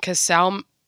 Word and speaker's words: Casale: His cars are pretty, and Casale rhymes with ka-chow.